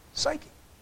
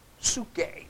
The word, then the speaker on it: Psuche: Psyche. Psuche would be the proper pronunciation, but I like psyche because you can understand that, how the English takes it up.